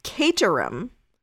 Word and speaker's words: Caterham: So apparently, Breck Bednar's like hometown or wherever they lived, whatever you call it, is pronounced Caterham, because Sophie's family lives there, and Sophie lives in a town nearby.